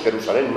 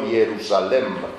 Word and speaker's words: Jerusalén: Incluso en palabras como Jerusalén decimos Jerusalén, no Jerusalem.